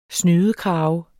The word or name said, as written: snydekrave